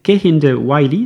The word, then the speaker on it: Kehinde Wiley: Son nom c'est Kehinde Wiley, ça s'écrit K-E-H-I-N-D-E e et Wiley, W-I-L-E-Y.